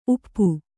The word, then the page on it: ಊಪು: ūpu